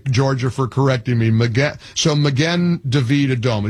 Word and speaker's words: Magen David Adom: I always say Magin David Adom. And somebody in Alpharetta, California, or Georgia said, you're not pronouncing it correctly. It's Magen David Adom.